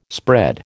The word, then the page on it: spread: American English